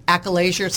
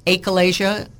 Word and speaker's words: achalasia: It's called achalasia, sometimes pronounced achalasia, depending on what continent your doctor's from. My sweet Indian doctor would say, your achalasia, but my American doctor's called it achalasia.